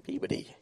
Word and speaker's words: Peabody: I try, you know, it's not Peabody, it's Peabody.